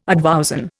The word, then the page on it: advowson: Indian